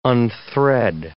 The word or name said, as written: unthread